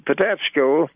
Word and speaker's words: Patapsco: And it was on the bank of the Patapsco that Francis Scott Key wrote the Star-Spangled Banner.